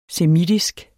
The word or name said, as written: semitisk